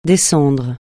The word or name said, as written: descendre